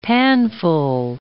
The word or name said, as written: panful